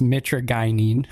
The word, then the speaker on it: mitragynine: but the 7-OH and then the mitragynine or I just recently learned it's mitragynine. – Mitragynine. – So finally I'm saying it right.